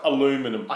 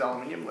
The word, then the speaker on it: aluminium: I think it is aluminium. Let me just double check. Yep, it is aluminium. Yep. – Oh, I said aluminum. – Aluminium, yeah, sorry. You said it wrong. If we're answering this question in the Northern Hemisphere, you'd be right.